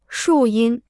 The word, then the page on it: 树荫: shù yīn